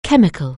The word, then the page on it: chemical: English